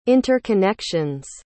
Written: interconnection's

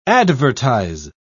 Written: advertise

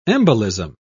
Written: embolism

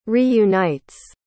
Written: reunites

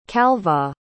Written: calvagh

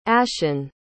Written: ashon

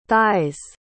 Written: thighs

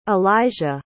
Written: elija

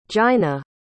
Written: giynna

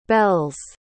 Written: bell's

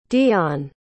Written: deonne

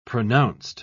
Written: pronounced

